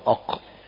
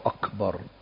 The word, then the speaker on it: أكبر: ويحذر جيم، يحذر من قلب الكاف قاف، الله أق، الله أكبر، أق، فهذا إبدال حرف بحرف، الكاف بالجيم لا تصح، الكاف بالقاف لا تصح.